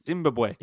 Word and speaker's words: Zimbabwe: He was born in Zimbabwe, if that's how you pronounce the country's name. And Zimbabwe.